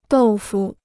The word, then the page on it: 豆腐: dòu fu